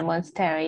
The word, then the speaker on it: Monstera: but the Monstera, is it right? Monstera leaf? – Yeah, Monstera, yeah.